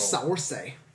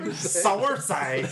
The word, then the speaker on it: Saoirse: Saoirse. That's how you say her name. That's how you pronounce her name.